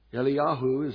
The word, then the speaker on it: Eliyahu: Elijah, Eliyahu is how the Hebrews pronounced his name, and it means my God is Jehovah.